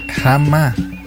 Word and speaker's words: jama: Que la palabra cuando se dice jama. Si se pronuncia jama.